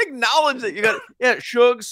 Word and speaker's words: Suge: S-U-G. – S-U-G, but it's short for sugar. It's Shug.